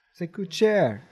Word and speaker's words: Se coûte cher: I think I said that right. Yeah. – Se coûte cher. – Yeah. That's how you say it.